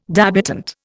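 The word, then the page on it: débutante: Indian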